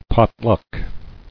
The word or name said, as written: Potluck